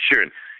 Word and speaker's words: Sheeran: Ed Sheeran, I think, Sheeran, yeah.